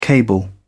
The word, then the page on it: cable: British English